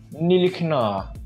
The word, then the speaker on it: Ntilikina: Mano, a pronúncia francesa é tipo Ntilikina.